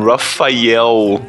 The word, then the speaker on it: Rafael: É, eu só quero corrigir a pronúncia. É, é The Brazilian Rafael.